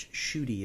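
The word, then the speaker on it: Ncuti: I kept saying Nukti Gatwa, but it's Shuti is how it's pronounced. – Yeah, I think the N is silent, it's just Shuti Gatwa.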